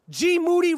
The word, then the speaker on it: G Moody: G Moody rhymes with duty and the Gringo Mandingo live July 28th in Minneapolis, Minnesota.